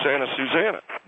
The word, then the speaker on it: Santa Susana: Santa Clarita, and I finally figured that's Santa Clarita, but Santa Susanana. And they said it's Santa Susana,